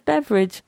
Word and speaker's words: beverage: It's got two syllables. – Beverage. – No, you're putting the E in there. No one pronounces that. It's beverage.